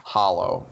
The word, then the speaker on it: Hallow: I will always pronounce it Hollow. – That's all right. – Opposed to Hallow. – I got to think of like Halloween. Even Halloween, yeah. I say hollow. I don't say Halloween.